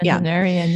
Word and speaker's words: centenarian: Is that how you say it? Centenarian. – Centenarian. Yeah. Yeah.